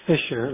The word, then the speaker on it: Fischer: Last name is Fisher. Let me spell it. F-I-S-C-H-E-R. – F-I-S-H-E-R. – No, it does have a C. F-I-S-C-H-E-R.